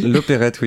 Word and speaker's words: Loperhet: Ah d'accord, donc ça se prononce bien comme ça, l'Opérette. – L'Opérette. – Et ça s'écrit L-O-P-E-R-H-E-T. – Ouais, tout à fait, ouais.